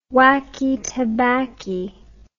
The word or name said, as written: wacky tabbacky